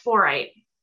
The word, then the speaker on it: fluorite: So that's fluorite. It's close to calcite, but it's fluorite.